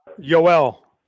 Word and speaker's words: Yoel: And pronounce that first name again. It's Yoel, right? – Yoel.